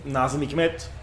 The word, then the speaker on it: Nazım Hikmet: Nazım Hikmet, el poeta. Ajá. Se escribe N-A-Z-I-M. Bien. Luego Hikmet, H-I-K-M-E-T, por si queréis buscar.